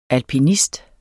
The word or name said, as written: alpinist